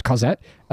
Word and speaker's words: Cosette: This is a French word. They say it's meant to be pronounced Cosette, but I feel like that's not correct. It's C-O-S-E-T-T-E.